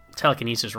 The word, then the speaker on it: telekinesis: he puts the emphasis on the wrong syllable, as they say. – Syllable. – But that's just classic Shatner. I don't know if it's Canadian or if it's Shatner, but how do you say telekinesis? – Telekinesis. – Okay, yeah. – Yeah. – Well, you're correct.